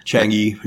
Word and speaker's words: Chang'e: Then we're going to do the Chang'e, is that how you pronounce it? – Chang'e.